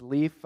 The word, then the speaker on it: Leaf: My name's Leaf. Uh, the name is spelled just like on a tree.